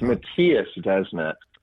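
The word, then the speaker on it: Mattias Desmet: So how would you, this type of scenario that this Matthias Desmond just wrote. I'll let you. How do you pronounce that name? You pronounce it. – I believe it's Matthias Desmet, because it's a Belgian name.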